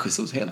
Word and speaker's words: Jesus: Gabriel Jesus i City. – Ja. – Som snyggt uttalade, annars får ni med mejla. – Ja, nej, Jesus, helt korrekt.